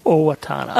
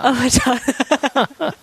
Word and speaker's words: Owatonna: But we'll be coming at you from, I don't know how to pronounce this, Owatonna? – Owatonna. – Owatonna.